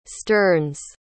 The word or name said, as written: sterns